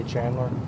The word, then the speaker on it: Chandler: I'm Detective Anthony Chandler. C-H-A-N-D-L-E-R. – I'm Sergeant Robert Nielsen, N-I-E-L-S-E-N.